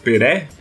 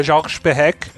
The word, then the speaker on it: Perec: Como é que se pronuncia isso? É George Peré? Peré? Perec? É Jorge Perec.